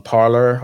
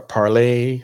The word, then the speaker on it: Parler: Also, you can get us on Parler or Parlay, however you want to say that. Is Parlay is French, but it's spelled Parler.